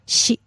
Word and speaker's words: shi: Because 4 in Japanese is sometimes pronounced shi.